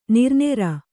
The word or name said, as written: ನೆರೆನೀರು